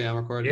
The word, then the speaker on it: Amarcord: Am I saying that correctly, Amarcord? – Yeah, which is also about fascism.